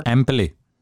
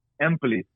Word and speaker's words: Empoli: Empoli. Not the side with Dolphin on the fence. – Empoli. – Empoli, there we go.